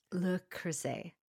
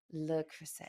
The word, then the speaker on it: Le Creuset: You do speak But it's le, not le. And okay. Right. Creuset is creuset. And some people say le creuset.